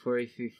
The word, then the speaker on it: porphyry: Porphyry, Brian. Okay, seriously, that's how you pronounce it. – There's no P. There's no PH porphyry.